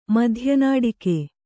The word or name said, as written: ಮಧ್ಯನಾಡಿಕೆ